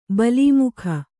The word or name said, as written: ಬಲಿಮುಖ